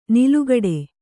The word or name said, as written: ನಾಲುಕಟ್ಟು